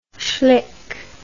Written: schlick

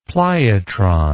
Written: pliotron

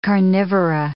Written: carnivora

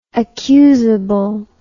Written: accusably

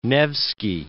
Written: Alexander Nevsky

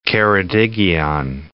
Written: Ceredigion

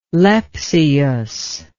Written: lepsius

